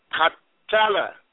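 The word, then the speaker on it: Qatala (قتّل): And when we go to the second form, it becomes qatala. And you hear the emphasis on the T there, two T's there.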